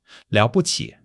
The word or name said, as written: liao3 bu5 qi3